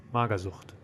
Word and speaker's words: Magersucht: Magersucht. – Sucht. Sucht. Spell it. – Mager, M A G E R, and Sucht, S U C H T. Magersucht.